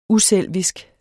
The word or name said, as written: uselvisk